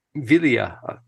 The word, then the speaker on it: Gvilia: Gvilia, yeah. – Gvilia. The G is silent.